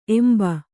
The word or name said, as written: ಎಂಬ